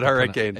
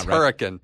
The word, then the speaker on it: Hurricane: It's H-U-R-R-I-C-A-N-E. H-U-R-R-I-C-A-N-E. – And it's not – so it's called Hurricane or is it Hurricane? – It's not Hurricane. It's Hurricane.